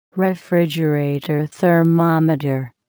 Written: refrigerator thermometer

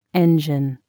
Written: engine

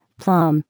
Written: plum

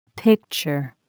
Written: picture

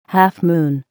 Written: half moon

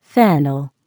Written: fennel

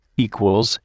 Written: equals

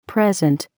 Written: present

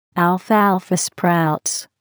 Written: alfalfa sprouts

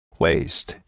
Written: waist

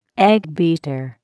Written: eggbeater

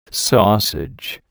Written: sausage

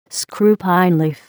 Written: screw pine leaf

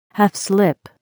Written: half slip

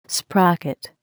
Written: sprocket

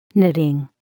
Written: knitting

